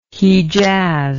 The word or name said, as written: hijaz